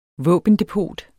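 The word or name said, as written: VÅBENDEPOT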